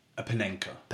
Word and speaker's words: Panenka: So 630 for Barcelona, 70 for Argentina, and his 700th goal was a panicker. – A Panenka. – Panenka. – Yes, a Panenka penalty, like, amazing. I always say it wrong.